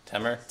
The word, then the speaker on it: Temur: Temur. It rhymes with Femur.